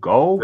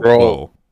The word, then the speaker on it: Grohl: Is that how you pronounce that? Grohl? Gull? – Grohl.